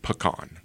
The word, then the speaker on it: pecan: If you ever look in a dictionary pre 1960s, there's only one pronunciation, pecan. – Mm-hmm. – That's the only way that word is pronounced. Okay. Any other way is incorrect.